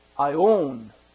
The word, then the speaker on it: aion: I worked on this today, and the word is aeon, A-I-O-N. In the Greek, it's pronounced aeon, and it's a noun.